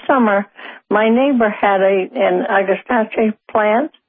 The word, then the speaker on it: Agastache: Next up, we've got a question called Agastache. I believe I'm saying it right. – You're close. It's Agastache.